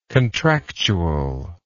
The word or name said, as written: contractual